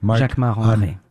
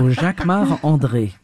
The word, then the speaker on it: Jacquemart-André: Jacquemart-André. Or no, you say it like André. A Frenchman. – Jacquemart André. Of course, because I'm French. So Jacquemart André.